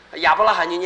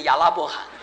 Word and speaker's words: 亚伯拉罕: 你们读起圣经来五六十年腔调还不对呢，亚伯拉罕就念成亚拉伯罕。